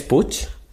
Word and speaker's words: Puig: ¿Cómo se pronuncia Puig? Yo qué sé. Puig. El apellido catalán que es P-U-I-G. – Puig. Puig. En español es Puig. – Puig. ¿Es Puch. – Puch. – Es verdad, Ricky Puig.